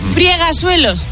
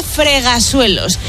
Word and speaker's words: fregasuelos: Friegasuelos, no se dice. – No. – Se dice fregasuelos.